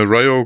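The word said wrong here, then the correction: Riho: gegen äh, Rio. Nicht Ryo, Rio spricht man sie aus gegen Nyla Rose.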